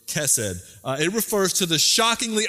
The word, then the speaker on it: chesed: You've got to get the Middle Eastern like chesed. I'm not going to do that.